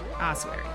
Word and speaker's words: ossuary: Ossuary, right. – That's what I thought even though I said it wrong twice.